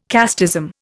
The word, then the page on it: casteism: Indian